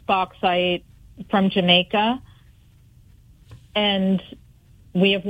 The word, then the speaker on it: bauxite: We mentioned some of the environmental impacts of mining the bauxite.